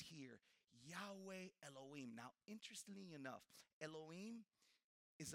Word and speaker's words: Yahweh Elohim: In the original language, which is Hebrew, would be pronounced Yahweh Elohim.